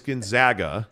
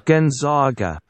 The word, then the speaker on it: Gonzaga: And by the way, I got the high-level explanation that it is Gonzaga. – Gonzaga. – I got like 10 people who are like, hey, man, I paid for my degree from Gonzaga, and I'd appreciate it if you'd pronounce it the right way.